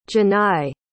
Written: Jenai

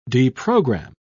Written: deprogram

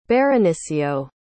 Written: baronicio

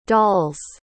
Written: dolls

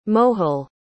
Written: mojil

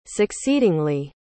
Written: succeedingly

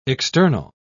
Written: external

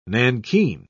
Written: nankin

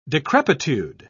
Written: decrepitude

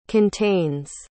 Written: contains

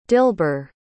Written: dilber

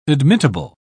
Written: admittable

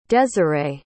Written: dezirae

